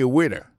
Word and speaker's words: waiter: Alors moi, je prononce le waiter. Waiter, ça veut dire accent tombé sous le premier syllabe là. Waiter, W-A-I-T-E-R.